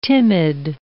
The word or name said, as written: timid